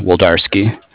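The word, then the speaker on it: Wlodarski: It's W-L-O-D... A-R-S-K-I, Woldarski.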